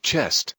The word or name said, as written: chest